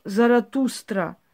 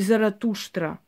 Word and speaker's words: Заратуштра: Вот времена ариев, религия ариев, один из великих пророков Заратуштра – так и называется он во вести, поэтому его так и произносим, потому, э, поскольку раз, различные есть произношения. В любом случае, Зараастер называли его армяне и греки. Зара, Заратустра или Заратуштра в основном на Востоке так и звучит.